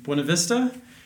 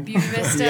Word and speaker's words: Buena Vista: And we're from Buena Vista, even though I just said it wrong. It's how much time I'm home. – Buena Vista, yeah, Colorado.